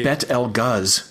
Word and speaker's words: Betelgeuse: Is it Betelgeuse? – I don't know. – It's a movie. – Okay, it's a movie. – Technically it should be pronounced Bet El Guz.